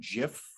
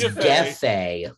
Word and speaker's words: Jefe: I've been saying Jif for so long. no, it's Gefe.